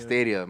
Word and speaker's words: Stadia: That's how you say it. Stadia.